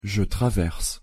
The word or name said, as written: je traverse